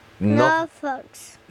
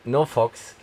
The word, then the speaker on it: NoFX: Weißt du, wie die Band ausgesprochen wird? – Norfox. – Norfox, genau. NoFX.